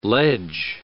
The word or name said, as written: ledge